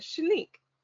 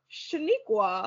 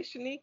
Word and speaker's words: Shanique: In my head, I'm kind of like, well, that was interesting that you added A's where there were none, but okay. The offense is when, like, I'm at work and we're in a meeting and everyone has said Shanique, Shanique, Shanique. And then here comes Bob Shaniqua. And I'm like, so you didn't hear everybody else say Shanique?